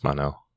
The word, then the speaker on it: Mon-El: So it makes sense to say Mon sometimes, but usually pronounced Mon-El. That's how they've been saying it all season, but Cat Grant still says Kira.